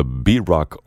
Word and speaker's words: baroque: Handel on WRTI, his Water Music Suite number 2 in D major, Dmitry Sinkovsky leading the B-Rock Orchestra. That's a fun name for an orchestra playing on the British pronunciation of the word baroque. They say baroque in British English.